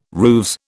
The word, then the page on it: rooves: British English